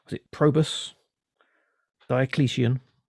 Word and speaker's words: Diocletian: Diocletian. And I had to I had to write down how to pronounce Yes. – Correct. That's correct. Diocletian.